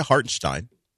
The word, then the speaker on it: Hartenstein: Because if you missed it, Isaiah Hartenstein, which I finally figured out is a proper way to say it